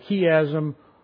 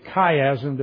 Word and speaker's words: chiasm: You recall many times that David has shown us up here that a chiasm or a chiasm, depending upon how you pronounce the Greek letter that looks like an X and it sounds like a K. Okay, you either say it ki or chi.